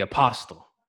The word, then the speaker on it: apostle: I got the uh the apostle. – Yes. Ooh. – The T is the T the T is silent, by the way.